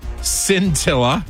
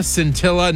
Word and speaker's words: Scintilla: S-C-I-N. – I guess it just rhymes with Priscilla, Scintilla.